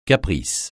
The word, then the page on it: caprice: francais